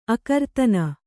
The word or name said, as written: ಅಕರ್ತನ